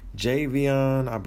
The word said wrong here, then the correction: Javon Wims: I want to make sure I get his name right. Javon Wims. Yes. So Javon Wims, from the Chicago Bears, wide receiver, Javon Wims is suspended from the NFL.